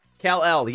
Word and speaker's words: Kal-El: Nicolas Cage says that his son, Kal-El, is that how you say it? Kal-El? – Kal-El, yes, from Superman.